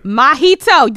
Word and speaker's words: Mahito: Mahito. Do, not you have the cast Mahito. list right in front of you? – I can't pronounce these names. – It's like mojito, but with an A.